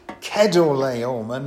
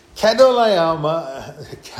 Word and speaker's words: Chedorlaomer: I always used to pronounce it Chedorlaomer, and when I couldn't sleep in the week, I was trying to think of all the first names that were cheese-related. It didn't come to many. There was Brie. And there was Leicester, but if you can think of any more, please tell me later. But apparently it should be pronounced Chedorlaomer, not Chedorlaomer.